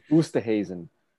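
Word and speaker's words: Oosterhuizen: 30 people, way easier, especially guys who have like weird names, like Ustafin, that dude. – Oosterhazen. – There we go.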